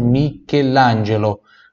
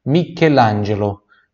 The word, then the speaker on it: Michelangelo: Com esse sono gli usar ti, então pronúncia pura do ti, da Michelangelo, CHE som de que por isso Michelangelo. Michelangelo.